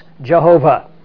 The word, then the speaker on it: Jehovah: And it is the formula for the personal name of God, which we pronounce Jehovah. You can pronounce it Yahweh.